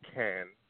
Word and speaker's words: Cannes: The way I pronounce it, at least, has always been can. – With no S. – Can of soup. Yeah, without the S.